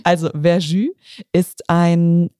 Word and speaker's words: Verjus: Also letztes Jahr war ich am Wurstmarkt dabei bei der Berichterstattung und da wurde uns ganz doll angepriesen, so ähm, ich will es jetzt nicht falsch aussprechen, Verjus oder Verjus. – Ja, Verjus.